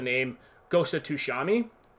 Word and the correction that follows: Tsushima: for the PlayStation Tsushima. Tsushima. So I started. Pl- it's a hard. It's really hard to say if you're not used to making that sort of sound.